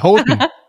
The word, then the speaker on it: Houten: Houghton. ja, sehr gut. ja, ähm, ich habe so viele Fragen zu dieser Location, Location-Auswahl, warum genau in Ho Houghton? Houghton. Houghton, Houghton. – Houghton, Houghton, Hauten.